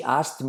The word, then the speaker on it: asked: She asked me Cuidado, esta pronunciación de pasado no es asked me, sino que la, a la, la K se omite.